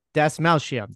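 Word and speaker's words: Dastmalchian: Das Malchium.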